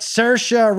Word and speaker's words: Saoirse: Saoirse Ronan, 5-1 odds? – Nailed it.